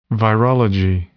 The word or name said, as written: virology